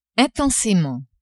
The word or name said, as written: intensement